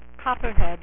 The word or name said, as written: Copperhead